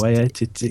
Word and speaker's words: Waititi: The writer-director, sorry for the pronunciation, is a guy called Taika Waititi? – Yeah, he's... – Waititi, yeah.